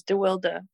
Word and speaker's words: de Wilde: Wild. Well, actually in Dutch, when I'm in Holland, that's how they pronounce it, is DeWilde.